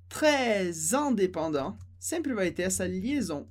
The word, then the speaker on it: très indépendant: Então, très, normalmente a gente não pronuncia o S, mas em très indépendant a gente pronuncia esse S com som de Z.